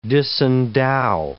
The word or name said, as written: disendow